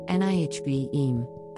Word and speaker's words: Nevi'im: defines this week's Jewish terminology word as a Hebrew noun pronounced Nihv'im, the second of the three books that comprise the Tanakh, along with Torah and Ketuvim.